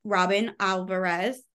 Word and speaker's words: Robin Alvarez: When Oceans Rise by Robin Alvarez, right? – Yep. – Did I say that right? – You got it.